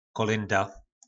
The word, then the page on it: kolynda: po našimu